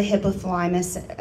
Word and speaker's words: hypothalamus: And from there, the hypothalamus, I think I said that right.